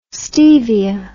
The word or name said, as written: stevia